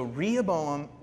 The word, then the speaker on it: Rehoboam: Now, Solomon's son's name was Rehoboam. You got it? Okay, it rhymes with Jeroboam. So Rehoboam, God got angry with Solomon.